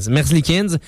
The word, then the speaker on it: Merzlikins: Ils reçoivent aussi le gardien Elvis Merzlikins. C'est-tu comme ça qu'on prononce, les gars? – Merzlikins.